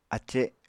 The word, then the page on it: ace: Apatani